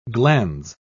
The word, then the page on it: glandes: British English